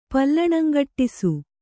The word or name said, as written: ಪಲ್ಲಣಂಗಟ್ಟಿಸು